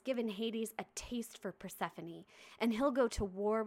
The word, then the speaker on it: Persephone: For a really long time, I had never heard Persephone pronounced. I'd only ever read it. And so... – Persephone. – Until I was 14. – Yeah, same. – And then I heard it and I was like, Persephone's way prettier than Persephone.